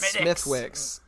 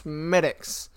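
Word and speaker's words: Smithwick's: Wait, so it's pronounced Smittix? There's a W. – Yeah, though. – But it's spelled Smith Wicks. Spelled. – Yeah. – Smells. It's spelled Smith Wicks. But I was corrected.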